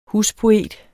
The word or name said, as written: huspoet